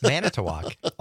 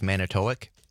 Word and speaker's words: Manitowoc: Makawiki, it's Manitowoc. Almost everybody got it right. Although I will say one of the mispronunciations was Manitowoc.